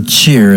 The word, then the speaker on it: Tchir: song from Trevor Cheer. It's spelled T-C-H-I-R. That's Mothers in the Garden.